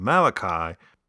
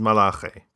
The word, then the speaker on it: Malachi: I'm sticking with the convention of pronouncing the name of the book the English way, but the names of the protagonists in the original Hebrew. So it's the Book of Malachi, and its protagonist is Malachi.